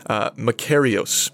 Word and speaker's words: makarios: You probably have it written in your study notes, but it's the Greek word makarios. And I'm 100% certain I've said that correctly.